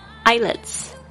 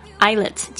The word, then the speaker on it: islets: Twelve islets, islets 记得这个 s 没有发音哦，小岛。